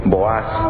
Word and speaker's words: Boaz: les dije que iba a pronunciar durante este estudio Boaz, porque es la manera como se pronuncia en hebreo. Y Boaz me parece muy osco, así es que vamos a decirle Boaz.